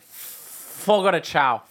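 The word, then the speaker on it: Fogo de Chão: I don't think you guys understand how much of an appetite I have whenever Fogo de Chao. – Fogo de Chao? – There's sponsored, by the way. – Wait, how do you say it? – Fogo de Chao.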